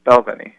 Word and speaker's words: Balvenie: It's Balvenie.